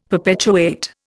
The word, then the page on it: perpetuate: Indian